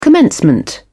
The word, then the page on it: commencement: British English